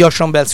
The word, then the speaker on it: Ocean Bells: Questa, come direbbe Marcy e Ocean Bells, detto bene? – Sì. – Erano gli Ocean Bells, scusatemi, con Faster Than Sound.